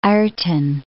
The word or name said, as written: Ireton